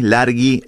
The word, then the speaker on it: Larghi: Bueno, con mi nombre tiene una H entre la G y la I, Largi, se escribe Largi o Larghi, es Maxi Largi con H entre la G y la I.